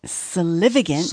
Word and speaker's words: solivagant: Sully Vagant. I'm saying that wrong. Solivagant? – Solivagant. – S-O-L-I-V-A-G-A-N-T. Vagant. Solivagant.